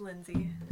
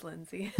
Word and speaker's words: Lindsay: It's just Lindsay. – Yeah. – It's spelled with an A, but it's just Lindsay.